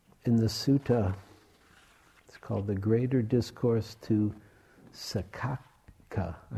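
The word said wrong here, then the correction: Saccaka: Sachaka, thank you. Sachaka. Double C, Sachaka.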